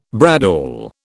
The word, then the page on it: bradawl: British English